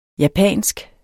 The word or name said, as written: japansk